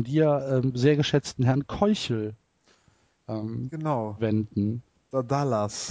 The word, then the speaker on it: Keuchel: Ähm, und Dallas Keuchel oder Keikel, wie er in Amerika ausgesprochen wird, hat ein ERA Plus von 662.